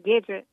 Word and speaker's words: Gidget: It's pronounced Gidget, like the show.